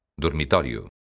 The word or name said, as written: dormitorio